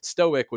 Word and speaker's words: Stoic: And there's a bit of a pun there that doesn't quite land with our contemporary pronunciation, but Stoic would be pronounced sort of closer to the word stock, which itself could also mean a kind of fool.